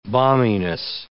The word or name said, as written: balminess